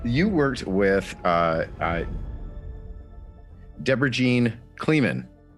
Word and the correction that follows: Creelman: on, am I saying that right? No? – Creelman. – Deborah Jean Creelman.